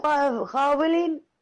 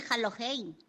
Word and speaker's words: Halloween: Feliz Halloween, grupo, soy Vanessa. – No sé quién eres, pero me parece que no se dice así, se dice Halloween.